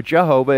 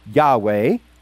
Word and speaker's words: Yahweh: Some people mispronounce it as Yehovah or Jehovah, it's actually Yahweh.